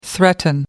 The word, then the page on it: threaten: American English